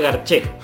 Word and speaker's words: Garcé: Por ese nombre, por los descendientes de Italia, que se pronunciaría Garché. Porque me recuerda momentos agradables de la vida.